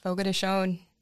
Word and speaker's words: Fogo de Chão: Fogo de Chão, if you didn't know that's how you pronounce it.